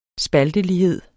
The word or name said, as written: spaltelighed